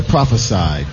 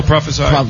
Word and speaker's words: prophesied: Prophesized. – Yeah, prophesied, not yeah. prophesized. – Oh, prophesied.